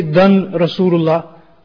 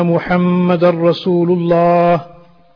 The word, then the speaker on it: Rasulullah: Then the dar Rasulullah, we don't say dan Rasulullah. We don't pronounce the tanween here, but we join it straight onto the ra, and we say ashhadu anna Muhammad Rasulullah.